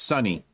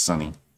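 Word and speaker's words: Suni: We were like, without Simone, it's pronounced Sunny. My bad. Shorty just texted me. She says, pronounced Sunny.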